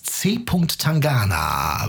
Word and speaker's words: C. Tangana: C. Tangana.